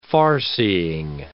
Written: farseeing

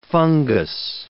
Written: fungous

